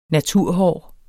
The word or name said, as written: naturhår